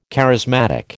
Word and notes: charismatic: American English

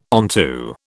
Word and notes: onto: British English

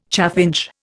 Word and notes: chaffinch: Indian

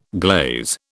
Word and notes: glaze: British English

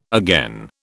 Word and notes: again: British English

